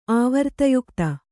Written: ಆವರ್ತಯುಕ್ತ

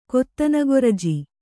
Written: ಕೊತ್ತನಗೊರಜಿ

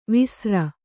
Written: ವೇಸರ

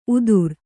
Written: ಉದುರ್

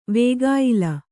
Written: ವೇಗಾಯ್ಲ

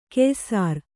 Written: ಕೆಯ್ಸೇರ್

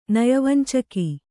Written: ನಯವಂಚಕ

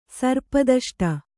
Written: ಸರ್ಪದಷ್ಟ

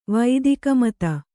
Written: ವೈದಿಕಮತ

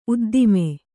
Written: ಉದ್ದಾಮ

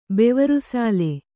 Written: ಬೆವರುಸಾಲೆ

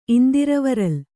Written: ಇಂದಿರವರಲ್

